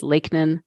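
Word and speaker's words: Lakenan: If you're just listening, it's J-E-N-N-I-E. And my last name is Lakenin, L-A-K-E, like the body of water, plus N-A-N, the first three letters of Nancy.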